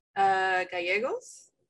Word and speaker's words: Gallegos: Don't be offended if you get it wrong, but can you pronounce his last name? – Gallegos. – Damn. – Yeah. She did she add that stay. Little flavor in there. – Yeah, that was good. Like that. Nailed it.